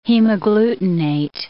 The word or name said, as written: hemagglutinate